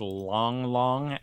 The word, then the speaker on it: Lang Lang: Yes, I was watching Lang Lang, the way it looks like it's spelled. It's a concert pianist, Long Long, L-A-N-G, and it's really enchanting.